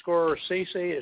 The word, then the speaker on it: Scorsese: but Martin Scorsese, is that right? – Yes.